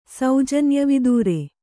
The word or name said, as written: ಸೌಜನ್ಯವಿದೂರೆ